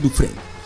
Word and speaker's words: Dufresne: Ya entonces se, se pronuncia Dufresne.